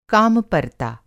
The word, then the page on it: काम-परता: कामपरता